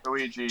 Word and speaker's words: Ouija: It's pronounced, I think, Ouija. – Because it sounds weird when you just say it like that. – Ouija. – Just think of, like, the back half of Luigi.